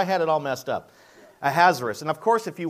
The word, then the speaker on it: Ahasuerus: Ahasuerus, okay, Ahasuerus. It sounds hazardous, but it's Ahasuerus. In the first year of Darius, the son of Ahasuerus, I like that, by descent a Mede, who was made king over the realm of the Chaldeans, that's Babylonia.